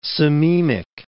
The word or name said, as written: sememic